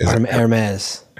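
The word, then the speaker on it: Hermès: Hermes.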